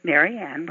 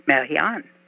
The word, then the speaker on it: Marianne: Finally, when I got to junior high school, I decided that I was going to change my name so that Marianne was spelled as one word. So I figured out that I would make it a French spelling, M-A-R-I-A-N-N-E. Now, the French pronounce that Marianne.